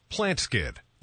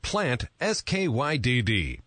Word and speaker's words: Plantskydd: Plant Skid. That's spelled plant, S-K-Y-D-D. Plant Skid. – In case you didn't hear that, it's PlantSkid, PlantSkydd.